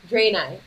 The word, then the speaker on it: Draenei: I am currently playing, is it pronounced Draenei? – Draenei, okay.